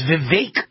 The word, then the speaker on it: Vivek: He said it rhymes with cake, so it's Vivek Ramaswamy, but it's not easy remembering that or pronouncing that.